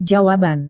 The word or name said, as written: jawaban